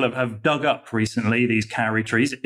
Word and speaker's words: kauri: In particular, there are these trees called kauri trees that are in New Zealand.